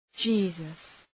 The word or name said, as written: Jesus